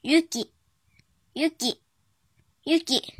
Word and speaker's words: 雪: 训读的时候读作 yuki，yuki，yuki。